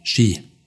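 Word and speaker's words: Shi (四): 40.000 Yen sind ohnehin tabu, weil die Zahl 4, wie Shi, also Tod, ausgesprochen wird.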